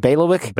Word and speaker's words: bailiwick: So this question is a bit outside our bailiwick, Since we aren't, did I say that right? Bailiwick? – Bailiwick, yeah.